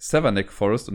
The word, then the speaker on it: Savernake Forest: Und der heißt Severnack Forest und nicht äh, so, wie ich es halt letztes Mal falsch ausgesprochen habe.